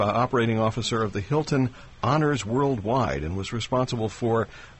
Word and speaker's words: Honors: It's just Honors. – Okay, because in your bio it's got... – There's two H's, but the first H is silent, kind of a Honors.